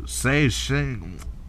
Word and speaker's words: Zhejiang: Ying Fuming, a manager at the Fanchang Grease Factory in Dazhou, a city in Zhejiang, whatever. God damn it, I hate saying these weird names. – Spell it. – Okay, Z-H-E J-I-A-N-G. – Go. J-I-A-N-G? – Yeah. Z-H-E-J-I-A-N-G. – Zhejiang.